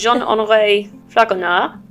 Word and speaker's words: Jean-Henri Fragonard: And he was, let me get this pronunciation correct, because I looked it up earlier. So he was Jean-Henri Fragonard. – That sounded pretty good.